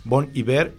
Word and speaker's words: Bon Iver: También está Bon Iver, que se pronuncia totalmente como se escucha, Bon Iver.